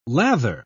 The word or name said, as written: lather